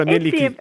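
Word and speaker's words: Mielikki: Miellicchi, il nome della dea finlandese, che bellezza. – Sì, sì, sì, l'avete pronunciato perfetto.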